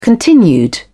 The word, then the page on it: continued: British English